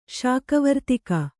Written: ಶಾಕವರ್ತಿಗೆ

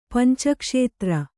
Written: ಪಂಚಕ್ಷೇತ್ರ

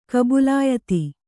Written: ಕಬುಲಾಯತಿ

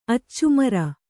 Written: ಅಚ್ಚುಮರ

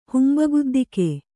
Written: ಹುಂಬಗುದ್ದಿಕೆ

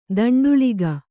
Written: ದಂಡುಳಿಗ

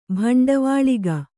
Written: ಬಂಡವಾಳಿಗ

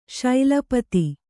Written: ಶೈಲಪತಿ

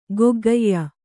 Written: ಗೊಗ್ಗಯ್ಯ

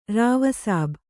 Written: ರಾವಸಾಬ್

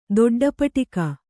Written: ದೊಡ್ಡಪಟಿಕ

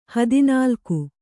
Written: ಹದಿನಾಲ್ಕು

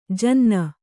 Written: ಜನಾನಾ

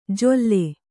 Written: ಜೊಲ್ಲೆ

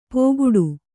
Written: ಪೋಗುಡು

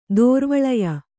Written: ದೋರ್ವಳಯ

